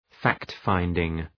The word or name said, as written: fact-finding